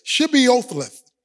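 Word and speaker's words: Shibboleth: They would say to them, they would pronounce a word to them, Shibiothleth. But they pronounced it differently in verse 6, with an S-I-B instead of an S-H-I-B.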